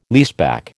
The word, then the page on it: leaseback: American English